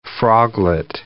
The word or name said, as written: froglet